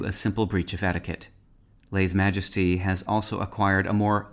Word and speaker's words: lèse majesté: In modern French, lèse majesté is pronounced lèse majesté.